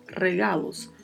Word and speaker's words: regalos: Pode ser no singular também, regalo, ou no plural, regalos. Presente, presentes. Como está no plural aqui, presentes. A pronúncia já começa com um vibrato do R, regalos.